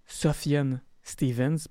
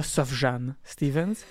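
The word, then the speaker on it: Sufjan Stevens: Ça se prononce Sofiane Stevens, pas Sofjane Stevens.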